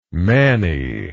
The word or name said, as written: mannie